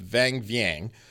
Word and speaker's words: Vang Vieng: Sorry, just talking about Friends just reminded me of when I was in Laos, there's a town called Vang Vieng.